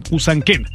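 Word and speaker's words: Usaquén: Resulta ser que en un área muy, muy lujosa, muy prestigiosa allá en Bogotá, Colombia, un área que se llama Huracané. Déjeme ver si lo pronuncié bien porque se está medio raro. Pero bueno, es Usanquén.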